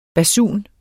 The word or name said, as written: basun